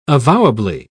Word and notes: avowably: British English

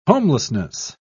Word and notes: homelessness: British English

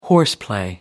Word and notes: horseplay: American English

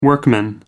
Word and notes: workman: American English

